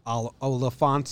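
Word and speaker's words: Olyphant: Oliphant sounds like elephant.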